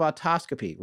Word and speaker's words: autoscopy: is a form of autoscopy.